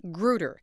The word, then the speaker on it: Grutter: We'll hear argument now on number 02241, Barbara Gutter versus Lee Bollinger. – That's Barbara G-R-U-T-T-E-R. She actually pronounces it Grutter, but has long since accepted that the case name is known in the legal world as Grutter.